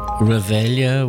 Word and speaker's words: Reveille: Revelia was the pronunciation. Sir Walter Scott the rhyme wouldn't have worked if it was Reveille.